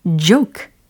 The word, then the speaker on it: joke: joke, joke. 그렇죠. 이 발음도 아주 연습을 많이 해보셨을 거예요. 조크 아니고 joke. 이렇게요.